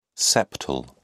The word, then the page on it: septal: British English